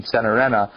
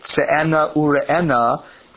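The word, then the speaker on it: Tze'enah u-Re'enah: And it comes from usually it's pronounced with a Yiddish pronunciation, Senarena, yeah. But Se'ena Ure'ena,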